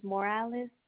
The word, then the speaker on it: Morales: Yes, first name is Viviana, spelled with the letter V-I-V-I-A-N-A, and last name is Morales, spelled M-O-R-A-L-E-S.